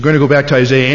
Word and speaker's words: Isaiah: By the way the British say it, Isaiah, that's closer to the way it should sound in the Hebrew.